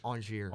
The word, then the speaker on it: Angers: Angers. – Angers, got to pronounce it French. – And PSG have Brest. Not as in, like... – 16th. The B-R-E-S-T.